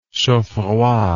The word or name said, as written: chaudfroid